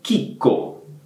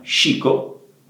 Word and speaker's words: Chicco: Quindi, ad esempio, vedendo questa marca, ecco, è importante che la pronunciate come chicco, ok? Chicco e non chico, Va bene?